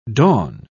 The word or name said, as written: dawn